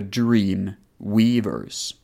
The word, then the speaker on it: Dream Weavers: Eh, jag kommer säga titeln igen, för jag kanske uttalar lite fel. Robin and the Dream Weavers. Så det är väl drömvävare som är sista ordet då på engelska. Dream Weavers.